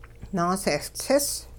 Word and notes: naasehLtsis: Hupa

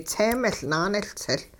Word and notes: tse-miL naanehLtsiL: hupa

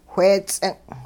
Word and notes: wheets'ing': Hupa

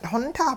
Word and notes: xontah: hupa

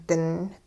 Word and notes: ding: hupa

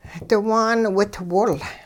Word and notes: diwaan witwol: hupa

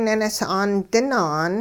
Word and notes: ninis'aan dinaan: hupa

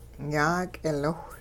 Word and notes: yaak'iliwh: Hupa

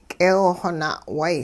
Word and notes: k'eew-xw na'way: hupa

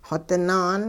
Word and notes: xodinaan: hupa

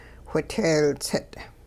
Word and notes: whiteeltsit': Hupa

